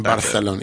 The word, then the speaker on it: Barcelona: In Barcelona. You got to say it like that.